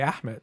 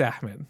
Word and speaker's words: Ahmed: They just say Ahmed. I was like, okay, for other people, it's Ahmed. You know, it's not just eh, it's like that's an acceptable way of saying it for them.